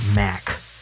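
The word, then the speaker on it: Macht: I think his last name is pronounced Mack, but it has a T at the end.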